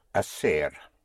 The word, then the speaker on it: hacer: To do or to make is hacer. Spelled H-A-C-E-R. Again, the H is not pronounced, so you have hacer, to do.